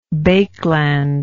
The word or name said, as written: baekeland